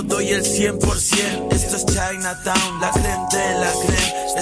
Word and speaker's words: Chinataun: Pero Chinatown, como se escribe fonéticamente, C-H-I-N-A-T-A-U-N. Chinatown, con las producciones de Asier y Tian, los sketches de DJ Ted Bundy y las rimas de Lactus y Piel Roja.